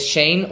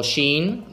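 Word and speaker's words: Shein: y digo operativa, que es Shane o Sheen, depende de, como De quieras pronunciarlo.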